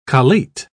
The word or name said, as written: collate